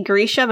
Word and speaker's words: Grisha: Because I say Grisha, but it sounds stupid. – I'm pretty sure the audiobook says Grisha. – The audiobook says it exactly like that, yeah.